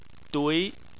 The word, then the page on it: dui: 2